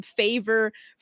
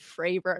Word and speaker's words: Fravor: If I had to say anything negative about this, was probably I felt like that David Fravor was being bullied because so many people got his last name wrong, calling him Favor, Frayer, Fair instead of Fravor.